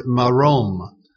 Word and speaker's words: Marom: Here we see the name Elohe used with the Hebrew word Marom. Marom, it's spelled M-A-R-O-M, and it means God of heights.